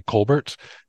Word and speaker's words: Colbert: which I believe the pronunciation of his last name is actually Colbert.